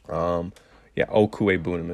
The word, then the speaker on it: Okwuegbunam: If you're not sure how to pronounce his name, Albert O, freak athlete out of Mizzou, played with Drew Locke. Okwuegbunam is how you would say his name.